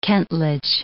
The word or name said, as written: kentledge